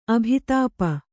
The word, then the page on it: ಅಭಿತಾಪ: abhitāpa